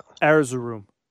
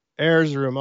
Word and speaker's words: Erzurum: How do you say that? – Erzurum. – Erzurum. Okay, I wasn't even close, but sorry, I don't know Turkish.